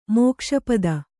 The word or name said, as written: ಮೋಕ್ಷಪದ